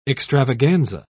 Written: extravaganza

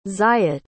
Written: zayit

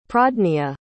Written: pradnya

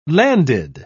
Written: landed

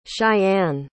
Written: shyann

